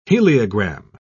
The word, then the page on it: heliogram: British English